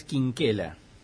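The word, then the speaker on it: Chinchella: Que la pronunciación en italiano de Chinchela es Quinquela.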